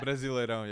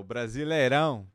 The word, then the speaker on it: Brasileirão: How do you say that? – Brasileirão, yeah. O Brasileirão.